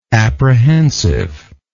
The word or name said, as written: apprehensiveness